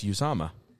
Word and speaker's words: Uzomah: I think is that how you say his last name? – Yeah, it's Uzomah. – Oh, shit! Been saying it wrong.